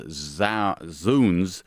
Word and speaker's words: zounds: A few listeners have written to tell us that zounds is pronounced zounds, a shortened form of God's wounds.